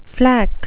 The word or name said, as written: flack